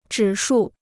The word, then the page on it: 指数: zhǐ shù